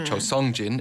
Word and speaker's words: Seong-jin: And with Cho Seong-jin then, or Cho Seong-jin, it's spelt in English like Sung, but we say Song.